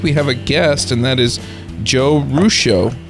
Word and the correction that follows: Ruscio: Did I say that right? Uh, close, yeah, Ruscio. Ruscio, okay. Yep. It looks Italian, and so my brain just kicked over to that pronunciation. – Yeah, it's six letters, but three syllables, phonemes. Packs yeah.